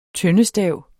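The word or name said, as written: tøndestav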